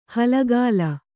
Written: ಹಾಲ್ಗಲ್ಲ

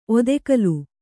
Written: ಒತ್ತುಗಲ್ಲು

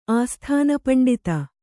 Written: ಆಸ್ಥಾನಪಂಡಿತ

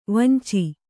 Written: ವಾಂಛೆ

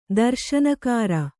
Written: ದರ್ಶನಕಾರ

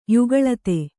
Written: ಯುಗಳತೆ

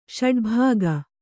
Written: ಷಡ್ಭಾಗ

